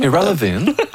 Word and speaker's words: Ira Levin: Ouais, je ne sais pas comment on prononce en anglais. Ira Levin. – Ira Levin. Ouh, ça sonne irrelevant un peu. C'est peut-être irrelevant.